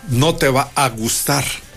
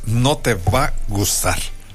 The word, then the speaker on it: No Te Va Gustar: No te va a gustar, ¿no? Porque además es como comúnmente se pronuncia. Este no, el grupo es No Te Va Gustar.